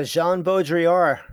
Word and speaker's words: Jean Baudrillard: Ah, uh, Jean Baudrillard.